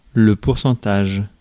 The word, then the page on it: pourcentage: France; /puʁ.sɑ̃.taʒ/